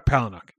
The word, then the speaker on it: Palahniuk: Chuck Palahniuk.